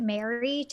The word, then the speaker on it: Mari: I call her Mari, but I think they pronounce it Mary technically. But M-A-R-I is Mari to me.